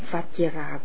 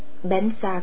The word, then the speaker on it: Vajra: Câu chú tôi khuyên các học trò tôi đọc là Om Ahom Vajra Guru Padma Siddhiyo. Người Tây Tạng nói Om Ahom Bensa Guru Pema Siddhiyo là thần chú của Padma Samphawa, của tất cả chư Phật, các bậc thầy và những bậc đã chứng ngộ.